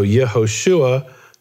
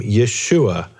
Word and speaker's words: Yeshua: Because many Jews in Second Temple times didn't pronounce the letter he as a he, they pronounced it as an really as an aleph. And so Nehemiahu became Nehemiah. The he essentially dropped. So Yehoshua became Yeshua. The he, pronunciation of the he dropped.